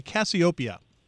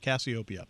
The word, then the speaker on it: Cassiopeia: I had mentioned the music that we're playing before as Cassiope, which I mispronounced. It is spelled Casio, you know, like the keyboard that David probably had as a kid growing up. Right, David? Casio? – Yeah. – Yeah, you still do? Yeah, good for you. – Yes. – And then P-E-A, as in the small round vegetable. But it's Cassiopeia is how it is correctly pronounced, Cassiopeia.